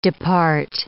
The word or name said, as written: depart